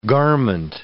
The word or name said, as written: garment